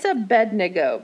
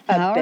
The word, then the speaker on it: Abednego: And it's Abednego because we were taught how to pronounce things in our church. – Abednego. All right. That's what you say. So I'm going to believe you. Although I looked it up. You're absolutely right.